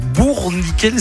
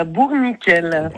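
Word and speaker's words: Bourniquel: Avec ici Périgord, elle habite à Bourg-Nickel, c'est comme ça qu'on prononce? – Tout à fait, à Bourg-Nickel.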